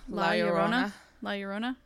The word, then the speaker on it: La Llorona: La Llorona? – Yeah.